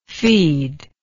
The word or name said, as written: feedable